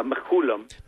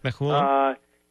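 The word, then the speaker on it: Mechoulam: Yeah, Menchulam. – Menchulam.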